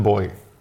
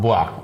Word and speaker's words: Bois: Und das ist Chapelle de Boy. Boy ist, glaube ich, falsch ausgesprochen. B-O-I spricht sich in Französisch. – Bois. Oh. Chapelle de Bois.